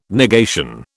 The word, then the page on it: negation: British English